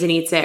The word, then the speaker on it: Anitza: And so it's easier for me to break the tension of saying, hey, my name's Anitza, it rhymes with pizza.